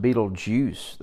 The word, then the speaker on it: Betelgeuse: But astronomers tell us that the star, I'm going to butcher this word, I speak Greek and Hebrew, but not astronomy, Betelgeuse, maybe is the word. Or I'm sorry, it's pronounced Betelgeuse. That's what it is.